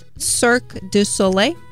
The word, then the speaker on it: Cirque du Soleil: They toured extensively across Europe, performed with Cirque du Soleil. Pronunciation, please. – Sorry, French listeners. Cirque du Soleil.